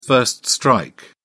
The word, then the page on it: first strike: British English